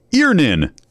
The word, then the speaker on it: Earnin: E-A-R-N-I-N is pronounced Earnin.